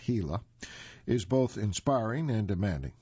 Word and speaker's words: Gila: And by the way, the reason I mentioned the pronunciation, it's spelled G-I-L-A, but again pronounced Gila.